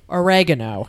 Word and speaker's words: oregano: The British pronounce it wrong. – Is it oregano? – It is oregano.